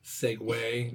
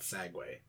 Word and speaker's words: Segway: Segs, not sags. Segway, not sagway.